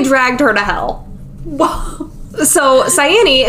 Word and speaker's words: Cyane: So out of all her friends, the one nymph, Cyane, that's what we're going to go with. That's what YouTube told me was going to be the pronunciation here. – Cyane.